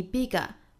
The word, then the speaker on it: bigger: 美音的话呢，我们就要注意 bigger 和 other 这里面呢要卷舌读成 bigger 或者是 other。